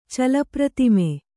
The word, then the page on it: ಚಲಪ್ರತಿಮೆ: kannada